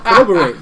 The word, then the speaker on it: corroborate: Oh, I don't need to say it. Collaborate. Nah. Corroborate. There we go.